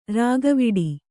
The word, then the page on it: ರಾಗವಿಡಿ: rāgaviḍi